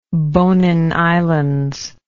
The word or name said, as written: bonin islands